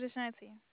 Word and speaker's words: Ashanti: My name's Ashanti. – Can you spell that for me, please? – A-S-H-A-N-T-I. – S-H-A-N-T-I.